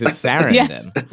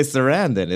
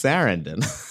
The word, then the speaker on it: Sarandon: He can't even pronounce her last name. It's Sarandon. He didn't say Sarandon. It's Sarandon.